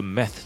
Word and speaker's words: meth: 那其实当然除了 meth 之外，它里面还有其他的代称嘛，比如说像是 crystal，哦，像水晶，因为据说这个冰毒呢，它的结晶状态就是长得像水晶一样。